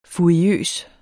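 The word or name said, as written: FURIØSE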